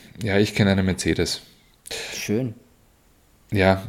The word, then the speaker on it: Mercedes: Übrigens wollte ich jetzt nur noch sagen, Unterberg ist wieder was anderes, ähm, aber das Mercedes Formel 1 Team wenn man das auf Englisch ausspricht, grundsätzlich Mercedes hat das drei E's, die alle drei verschieden ausgesprochen werden. Mercedes. – Mercedes. – Mercedes, ja.